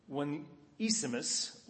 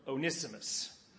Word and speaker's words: Onesimus: So also with Onesimus or Onesimus.